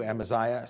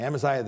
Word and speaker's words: Amaziah: Chapter 7, it's you, Amaziah. Sorry, I said his name wrong. Amaziah the priest.